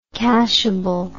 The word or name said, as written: cashableness